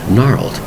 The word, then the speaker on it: gnarled: It's said to be gnarled looking, G-N-A-R-L-E-D. A different type of word, but remember in spelling, the G is totally silent when you do this. It's pronounced gnarled. G-N-A-R-L-E-D.